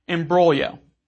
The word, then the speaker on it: imbroglio: Some people mistakenly pronounce the G, which is silent, and some, like I did when I first learned this word, mistakenly pronounce the I-O at the end as an E-O in words like folio and polio. However, according to most sources, it's pronounced imbroglio.